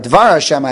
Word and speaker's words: Dvar Hashem: Dibar Hashem, I'm sorry, Dvar Hashem, I have the pasuk right in front of me. I should be able to pronounce it correctly.